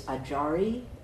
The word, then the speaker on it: ajari: So in Japanese, it's ajari, that's how you say it in Japanese, and in some sects of Buddhism, to be an ajari is to be the most senior person in that sect.